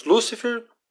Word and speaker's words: Lúcifer: Em polo oposto estão os diabos Lúcifer, sem acento, pois esta é a pronúncia que foi utilizada por Gil Vicente e era mais comum no século XVI.